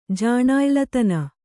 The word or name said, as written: ಜಾಣಾಯ್ಲತನ